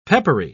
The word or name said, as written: peppery